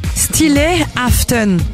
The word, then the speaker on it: Stille Aften: Alors, on prononce comment la soirée? – Stille Aften, qui veut dire soirée calme, littéralement.